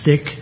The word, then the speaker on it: stich: It's spelled S-T-I-C-H. Stick.